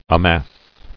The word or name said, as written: Amassed